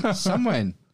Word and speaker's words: Samhain: Well, it's spelt Samhain, but it's pronounced Samhain. – Samhain. – Yeah, flip that M upside down. – Samhain. – Turn that frown. – turn that into a wane.